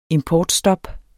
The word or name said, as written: importstop